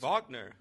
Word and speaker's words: Wagner: Oh, it's Wagner. – It's Wagner? – Mo Wagner. – Mo Wagner. Okay. – Yeah. That W's a V.